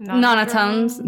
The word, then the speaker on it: Nonotones: So another plane comes in and they're like, you're not probably going to fight the army of, like, the mono drones or whatever initially. It's just going to be the... I'm sorry, how did you say it? – Nonotones. Nonotones.